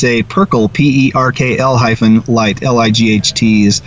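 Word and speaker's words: Perkl: In any case, it's a wonderful accident, and I just have to let my audience know that this Perkle light, and that's spelled P-E-R-K-L. I used to think it was, when I first heard about it, was purple, but no, it's P-E-R-K-L.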